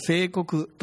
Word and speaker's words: 正鵠: 主法を見ていただくとですね、ものすごい難しい漢字が書いてあって、どうやって読むんだろうなって思うかもしれませんが、聖国と読むんですが、聖国というのは、弓道の的のことを言うわけですね。